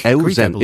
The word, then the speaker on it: Euzen: Euzen is an ancient Greek word meaning good life. – That's right. Eu or you sometimes gets pronounced in English. Like it's the same EU that's in euphoria.